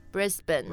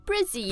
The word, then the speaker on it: Brisbane: or Brisbane. Appropriate. – 就比较像那个 B-E-N, 对吧 Ben,、mm-hmm. Brisbane.、 Yeah. So again, it's just the non-vowel, just Brisbane. – Brisbane. – Right. – Or as the locals call it, Brizzy.